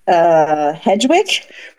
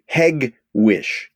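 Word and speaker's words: Hegewisch: H-E-G-E-W-I-S-C-H. H-E-G-E-W-I-S-C-H. – Hedgewick? – Very close. Very close. It's a hard G. Hegwish.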